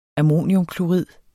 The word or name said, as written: ammoniumklorid